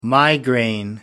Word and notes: migraine: American English

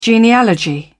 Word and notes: genealogy: British English

